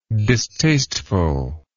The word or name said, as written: distastefulness